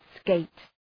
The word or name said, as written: skate